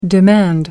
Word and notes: demand: American English

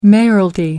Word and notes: mayoralty: American English

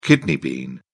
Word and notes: kidney bean: British English

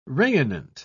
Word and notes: rayonnant: British English

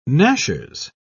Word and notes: gnashers: British English

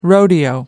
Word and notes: rodeo: American English

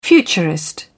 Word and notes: futurist: British English

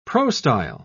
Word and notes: prostyle: British English

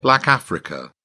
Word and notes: black africa: British English

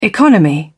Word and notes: economy: British English

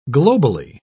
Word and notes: globally: British English

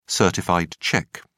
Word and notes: certified cheque: British English

